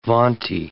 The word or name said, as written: vaunty